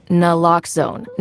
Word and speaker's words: naloxone: Naloxone reverses an opioid overdose, even if you mispronounce it. For the record, it's Naloxone. Nah, like, is Naloxone addictive? Nuh-uh. Locks like Naloxone keeps humans safe like locks keep bikes safe, and own like Naloxone can save lives in our very own communities.